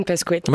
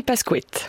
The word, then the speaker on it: Pascoët: Charline Pasquette, ça se dit bien comme ça votre ah, nom C'est Marine Pasquette.